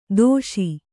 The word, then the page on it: ದೋಷಿ: dōṣi; kannada